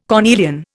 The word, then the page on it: cornelian: Indian